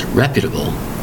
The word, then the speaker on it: reputable: Four syllables, spelled R E P U T A B L E. Now, notice how we spell this word. R E P is rep, U, ta, bull. Reputable, not reputable, it's reputable. Make it a flowing sound.